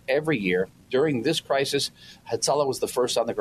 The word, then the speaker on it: Hatzalah: It may be the only word in Hebrew that I can pronounce, Hatzalah, because it's all A.